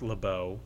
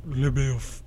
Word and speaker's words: LaBeouf: It's pronounced LaBeau. – Oh, LeBeouf. – LeBeouf.